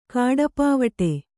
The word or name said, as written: ಕಾಡಪಾವಟೆ